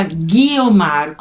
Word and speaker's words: Guiomar: il mio nome si pronuncia Ghiomar, come se ci fosse la H al posto della U.